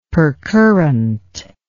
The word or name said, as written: percurrent